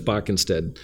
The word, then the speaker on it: Boeckenstedt: Yeah, so it's Boeckenstedt. – You say like the D, not the T? Boeckenstedt. – Yeah, so okay. It's not Beethoven, it's Boeckenstedt.